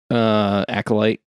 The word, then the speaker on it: Acolyte: On the other side, if you did the Asteroid M and Alkaloid stuff, Acolyte, however the fuck. There's not an L in that. I've always said that wrong.